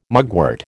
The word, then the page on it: mugwort: American English